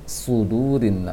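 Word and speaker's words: sudurin (صدور): Sekarang agak tebal sedikit, agak dinaikkan lisannya sedikit. Dan juga pangkal lisan diusahakan untuk terasa meninggi. Su. Su. Su. Dibacanya fi sudurin nas. Fi sudurin nas.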